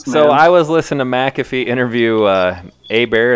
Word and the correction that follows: Herbert: Is that how you say it? The dude out of Oregon, right? – Herbert. Yep. – Herbert.